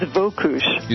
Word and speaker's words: Wokusch: We're talking with blogger and journalist Heather Wokush, W-O-K-U-S-C-H. And Heather, am I pronouncing your name properly? – Pretty close. It's Wokush.